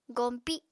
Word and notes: goñpi: Apatani

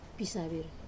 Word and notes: pisavir: wamesa

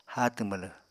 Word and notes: háatw mwlw: apatani

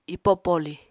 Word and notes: ipo poli: apatani

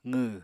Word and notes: qw: apatani

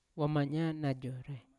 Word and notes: wamanya najorai: Yawa

